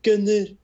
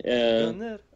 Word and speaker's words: GoNNER: that's actually the way to say GoNNER, is like GoNNER. – Okay. – That's the proper way to say the game title.